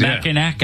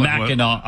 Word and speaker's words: Mackinac: When he goes to Mackinac Island? – Oh, no, Mackinac Island. – Mackinac Island. – First of all, yeah. – It's spelled, why do they spell it Mackinac when it's Mackinac?